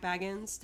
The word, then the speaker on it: Bagans: This is what it reminds me of, and not to bring up Zach Bagel again, but Zach Bagans. – Zach Bagel. – You pronounce his name Bagans.